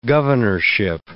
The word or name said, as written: governorship